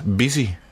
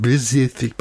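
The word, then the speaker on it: Pisiffik: Men det udtales busy. – Busy. – Pacifik. – Lad være med at sige Pacifik. Bare busy.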